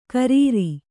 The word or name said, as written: ಖರಾರಿ